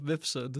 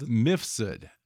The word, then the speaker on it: Mifsud: Joseph Misfood or Misfood? What is it? – Joseph Mifsud. – Mifsud.